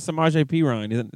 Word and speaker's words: Samaje Perine: That's how I felt about Samaje Perine.